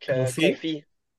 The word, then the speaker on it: confit: Duck, how do you say duck confit? – Duck confit.